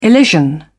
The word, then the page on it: elision: British English